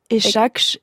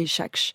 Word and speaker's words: Echakhch: Moi, c'est pareil. Je crois que c'est Echakche. – E-C-H-A-K-H-C-H. Mais oui, je pense que c'est une retranscription de l'arabe, du coup, c'est compliqué de prononcer.